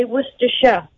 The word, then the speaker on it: Worcestershire: You don't have to say Shire. You just say Worcestershire. – Worcestershire. – Yeah. What? Worcestershire.